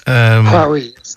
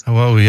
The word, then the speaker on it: Huawei: Because I remember around the time that, you remember Huawei, is that how we pronounce it? – Yes, Huawei. – Huawei, yeah.